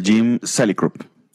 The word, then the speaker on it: Salicrup: te digo acá, Jim Salicrup. – Ajá. – Sí, lo estoy pronunciando bien, pero mis cojones, porque los gringos pronuncian mal el español, así que...